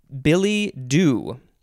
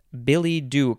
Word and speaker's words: billet-doux: And then the second word is doux, D-O-U-X. Do either of you speak French? – I took French in high school. – Did I pronounce that correctly, do you think? – I would pronounce it billet doux. – Billet doux. Yes, I think that is more correct.